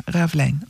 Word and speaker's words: Ravelijn: Aan het Ravelijn is dat volgens mij. – Ja, klopt. – Ja, hè? Ja. Is dat, ja, zeg ik dat goed? Ja, Ravelijn. Oké.